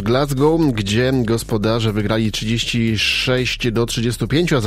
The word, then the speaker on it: Glasgow: Glasgow, bo tak się wymawia nazwę tego pięknego miasta w Szkocji.